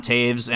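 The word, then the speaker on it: Toews: Then on defense, you have Devon Taves and Ryan Graves, which kind of rhymes, even though it's spelled very differently.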